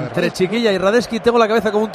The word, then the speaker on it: Radetzky: De sí, pero tiene una C que es Radetzky, es Radetzky. – Sí, se pronuncia Radetzky.